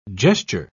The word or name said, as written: gesture